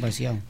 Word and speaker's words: Valseau: Pues este tema que hemos escuchado de Duerna, de su disco A Mansalva, se titulaba Valseau. Lo he pronunciado bien, Tono, porque como es asturiano, pues no sé, igual... – En Cantabria lo dirías exactamente igual, ¿eh? Un Valseau.